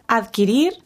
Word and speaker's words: adquirir: En inglés decimos acquire. En español, adquirir. Necesitamos pronunciar adquirir y omitir la U que pronuncian en inglés de acquire.